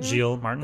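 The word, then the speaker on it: Giles Martin: That's what, how do you say his name? Gilles Martin? – Gilles Martin.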